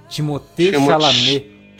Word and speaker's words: Chalamet: Timothée Chalamet.